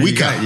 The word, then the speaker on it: Juica: And I have one of those experts here with me today, Mr. Eduardo Juica. – Yes, sir. – Did I say it right? – Yes, sir. Juica.